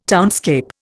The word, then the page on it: townscape: Indian